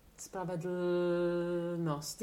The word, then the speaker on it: Spravedlnost: This L will turn into a very neutral vowel sound. And now she goes on to ask me how I would describe it. – Ja, Spravedlnost.